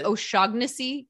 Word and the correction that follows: O'Shaughnessy: Did you just say the G? – Yeah. – O'Shaughnessy. It's O'Shaughnessy. The G is silent.